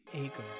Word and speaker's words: Acomb: It's Acomb. – Acomb. Now okay, on the sheet, on the call sheet, it says either Acomb or Acomb. And you're giving me the B is silent. – So it's a cum. Spell cum. C-O-M-E. A-C-O-M-E.